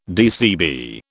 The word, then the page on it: DCB: British English